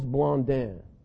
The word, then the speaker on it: Blondin: There was a tightrope walker back in the late 1800s named Charles Blondin. That's the way you pronounce it in French. We probably said Blondin.